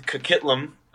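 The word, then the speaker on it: Coquitlam: And with Kakitlam, that's how you pronounce it, I believe.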